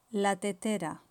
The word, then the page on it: la tetera: Spain Spanish